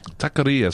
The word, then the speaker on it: Taqueria: Taqueria. It's all about the Ria, they tell me.